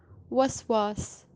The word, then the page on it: waswaas: Kashmiri